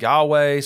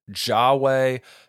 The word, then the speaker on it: Yahweh: A lot of times it's called the Tetragrammaton because it's abbreviated YHWH, but people pronounce it Yahweh. Some people pronounce it Jahweh, Jehovah, Yehovah. There's lots of different theories on how it should be pronounced, but just understand that that is talking about the exact same word.